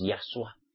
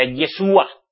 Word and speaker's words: Yahshua: Pues que yo sepa, solo hay un único mediador, que es Yahshua. Que por cierto, mesiánicos, vosotros ni lo pronunciáis bien. Tal vez sería porque como hay unas canciones por ahí de cómo se llama el Pol. Ese, no me acuerdo bien el apellido. El Pol, que decía Yeshua.